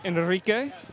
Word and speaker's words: Enrique: My name is Enrique. – Enrique! – There you go. – You gotta pronounce it. You gotta roll the R, it doesn't make sense.